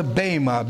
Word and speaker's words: Bema: In the Greek, it's called the Bema, B long E, pronounced as A, B-E-M-A.